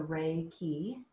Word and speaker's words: Reiki: And a translation of that is, so Reiki, that's how you pronounce it. And it could be like the rays of the sun and the keys to healing. It's a way to remember how to say it.